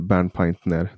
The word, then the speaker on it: Bernpaintner: Det finns ingen skam i det, men jätte, jättestort tack Klaus Bernpaintner. Sa jag rätt nu? Bernpaintner. – Ja. Det måste vara så vanligt att folk uttalar ditt namn fel. – De säger Bernpainter. – glömmer sista ämnet där.